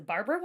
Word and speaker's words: Barbera: I don't even is a Barbera wine? – Is this, takes is with the bad. the babs. That how you pronounce it? No, it's Barbera.